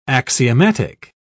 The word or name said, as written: axiomatic